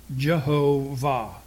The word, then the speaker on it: Jehovah: Now, we don't, the Yod is often in English transcribed to a J. So you would have Jehovah. is the pronunciation.